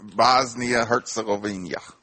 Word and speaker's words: Bosnia Herzegovina: Bosnia Herzegovina.